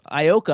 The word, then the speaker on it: Ioka: I guess it's like a digital electronic dance music artist, Ioka, I believe is how you pronounce it, I-O-K-A.